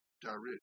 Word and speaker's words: darid: which is H-I-N, hin, and add the word darid, which is spelled D-A-R-I-D.